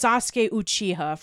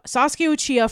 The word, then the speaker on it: Sasuke Uchiha: It's Sasuke Uchiha from Sasuke Uchiha. – Uchiha. Yeah, Sasuke Uchiha from Naruto.